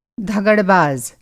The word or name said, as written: धगड़बाज़